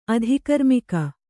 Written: ಅಧಿಕರ್ಮಿಕ

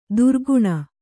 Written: ದುರ್ಗುಣ

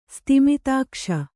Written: ಸ್ತಿಮಿತಾಕ್ಷ

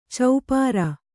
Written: ಚಪ್ಪಿರಿ

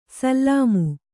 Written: ಸಾಲಿಮೆ

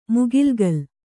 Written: ಮುಗಿಲ್ಗಲ್

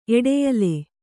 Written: ಎಡೆಯಲೆ

